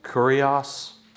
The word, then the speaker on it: kurios: All right, I'm going to spell it for you first, and then I'm going to pronounce it. Just write K-U-R-I-O-S. K-U-R-I-O-S. The word is kurios.